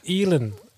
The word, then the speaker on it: Elon: Alan, also Alan, Alan Musk. Elon.